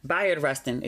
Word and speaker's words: Bayard Rustin: He was talking about this upcoming movie, Rustin, Bernard Rustin. Let me actually Google and make sure I'm pronouncing his name correctly. Bayard Rustin. Excuse me. I'm glad I looked it up.